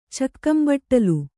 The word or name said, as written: ಚಕ್ಕಂಬಟ್ಟಲು